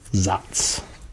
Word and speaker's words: Saaz: I don't know Saphir too well, but Zatz, as we explained last episode, the hop is spelled S-A-A-Z, but pronounced Zatz. By most people. Some people call it Zatz.